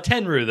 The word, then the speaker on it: Tenryu: Tenryu, though. That much I know, that's how you pronounce his last name.